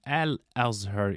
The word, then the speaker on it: Al-Azhar: In fact, the right pronunciation will be Al-Azhar University.